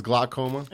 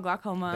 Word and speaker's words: Glockoma: And uh, the album is Glockoma. – It's called Glockoma. – That's and it's spelled like Glock the gun.